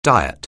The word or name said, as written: diet